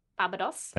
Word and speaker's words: Barbados: Sorry, could you pronounce it right? – Barbados. – Thank you.